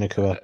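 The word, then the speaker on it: Nacua: Puka Nakua.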